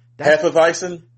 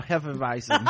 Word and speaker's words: Hefeweizen: It's something like Heisenweifen or some shit. – Hefeweizen? – There you go. Hefeweizen.